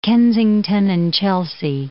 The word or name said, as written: Kensington and Chelsea